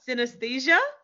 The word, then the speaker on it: synesthesia: So speaking of your seizure, you have something, and I doubt I'm going to pronounce this correctly, called synesthesia.